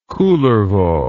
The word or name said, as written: kullervo